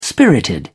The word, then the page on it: spirited: British English